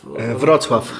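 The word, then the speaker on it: Wrocław: Wroclaw? – Wroclaw.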